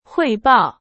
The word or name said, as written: 汇报